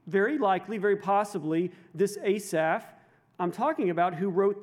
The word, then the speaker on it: Asaph: You can pronounce it differently in the Hebrew. Generally, we say Asaph.